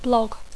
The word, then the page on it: blog: English